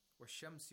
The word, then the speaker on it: الشَّمْس: So we have to pronounce the sheen with tashdeed without pronouncing the lam sakina. وَالشَّمْس.